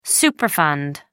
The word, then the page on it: superfund: American English